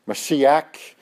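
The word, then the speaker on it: Mashiach (משיח): Messiah is a Hebrew word, Mashiach, correctly pronounced.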